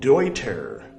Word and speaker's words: Deuter: Oh, sorry, I don't know if they're German or if they're Swedish or something, but the German pronunciation of EU makes it Deuter. Deuter. Because EU in German is Oi.